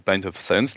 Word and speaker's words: Pint of Science: Les barres, donc tu t'en as parlé un petit peu, là, les Pint of Science, là, j'essaie de prononcer bien. Mm-hmm. En anglais, parfait. – Déplorable.